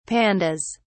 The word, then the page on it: pandas: British English